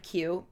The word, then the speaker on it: queue: Which I love that word queue because it's literally the letter Q and a whole bunch of silent letters lining up behind it.